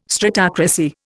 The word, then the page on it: stratocracy: Indian